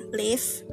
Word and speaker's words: Live: 18 episode drama Korea judulnya Live bacanya Live ya ah, benar Live